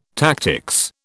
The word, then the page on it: tactics: British English